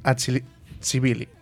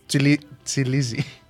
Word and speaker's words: Tbilisi: Va ser fundada el 28 de maig de 1918 pels membres àzeris de Seim de Transcaucàsia i militants del Consell Nacional Musulmà Tbilisi. No, vull dir malament. Tbilisi.